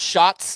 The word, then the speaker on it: Schatz: Kevin Schatz from the Join the Bandwagon podcast. – Welcome to the bandwagon. And it looks like Schatz, but it's actually pronounced shots, like shots of tequila, whatever.